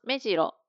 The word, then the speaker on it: 目白: 目白、この言葉もあります。目白、アクセント 1 です。